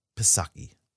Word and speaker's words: Psaki: Today, White House Press Secretary Jen Psaki said, this is, how do you say it? Psaki? Psaki. – Psaki. – Yeah. And who puts two vowels?